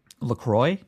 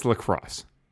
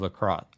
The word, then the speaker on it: LaCroix: LaCroix? You're thinking of LaCroix? – It's pronounced La Crosse. – No. Even if you were trying to pronounce it how it's spelled, you'd pronounce it LaCrosse.